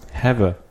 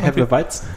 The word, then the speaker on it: Hefeweizen: Have. – Hefe, Hefeweizen? – Hefeweizen.